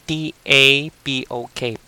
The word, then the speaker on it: tapok: It's P A N A G T A P O K, panagtapok, which is a noun. Or as a verb, it means tapok. T A P O K. Now, it came from the word tapok, the verb itself.